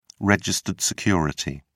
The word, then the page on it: registered security: British English